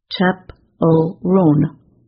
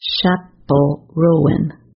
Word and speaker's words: Chappell Roan: The list says her name is pronounced Chappell Roan, not Chappell Roan.